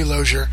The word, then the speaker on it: Loser: Her name is Zoe Loser, pronounced like closure.